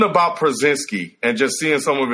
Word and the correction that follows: Porzingis: He said Przinski. – What is it? – That old man's a Porzingis. – Porzingis. Porzingis. Yeah, I don't know, man.